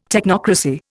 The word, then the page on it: technocracy: Indian